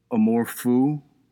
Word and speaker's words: amour fou: Like, why would you – that's amour fou, I believe is the pronunciation. It's French for crazy love.